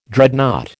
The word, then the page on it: Dreadnought: American English